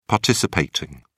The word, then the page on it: participating: British English